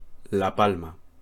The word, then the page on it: La Palma: Spanish